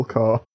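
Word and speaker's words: Corps: The Apple Core makes perfect sense. It's spelt C-O-R-P, like the military thing, but it's Apple Core.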